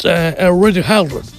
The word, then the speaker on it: Radio Garden: És Radio Garden. – Correcte.